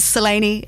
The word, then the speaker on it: Selene: And it's actually a very kind of twinkle in the eye, tongue-in-cheek first line of the show where we clarify for everyone that Selene is how we're saying it, as opposed to Selene, which lots of people tend to go to. but Selene is how we pronounce it.